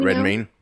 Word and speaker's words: Redmayne: Is it Red Redmayne or Redman? How does Redmayne? I think you're right. Redmayne. Redmayne. Yeah.